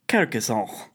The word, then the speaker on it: Carcassonne: I believe the correct pronunciation. Carcassonne.